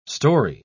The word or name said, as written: storey